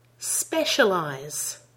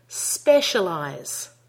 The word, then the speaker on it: specialize: Specialize. Check you're getting the syllable stress on the first syllable of that word. Let's try it again. Specialize.